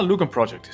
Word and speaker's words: Lugon Project: Did I say that right? Lugon Project? – Oh, Lugon Project is totally fine, yes.